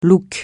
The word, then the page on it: look: francais